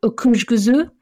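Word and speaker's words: Öküzgözü: And then in the reds, so I'd say the kind of friendly hero of Turkish reds is a grape variety called Okuzguzu, which is spelled O-K-U-Z-G-U-Z-U.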